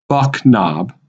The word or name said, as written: Buck Knob